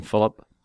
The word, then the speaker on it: Philip: I actually pronounced my first name Philip. – Philip. Okay. Yeah. Philip. Mr. Philip Sketty. – Philip Sketty. – But it's spelled P-H-I. – It's P-H-I. I prefer to pronounce the I as a U.